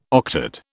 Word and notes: octad: British English

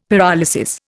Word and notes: pyrolysis: Indian

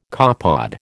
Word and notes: copepod: American English

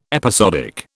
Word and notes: episodic: British English

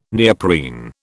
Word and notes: neoprene: British English

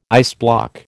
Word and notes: iceblock: American English

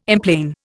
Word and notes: emplane: Indian

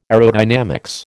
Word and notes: aerodynamics: American English

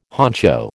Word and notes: honcho: American English

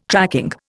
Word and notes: tracking: Indian

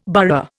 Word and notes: burgher: Indian

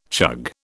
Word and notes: chug: British English

